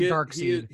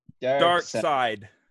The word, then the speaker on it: Darkseid: I think his name is Darkseid. – I think you're right. That's it. – That was it. – Nope. Yep. I think I'm wrong. I think I'm 100% wrong when I say Darkseid.